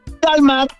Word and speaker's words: Dalmar: Se pronuncia en inglés Dalmar.